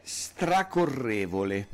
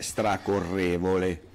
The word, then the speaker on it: stracorrevole: Allora, una di queste parole, per esempio, è stracorrevole. Con Ok. – l'accento sulla E, stracorrevole, ok? – Stracorrevole.